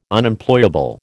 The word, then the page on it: Unemployable: American English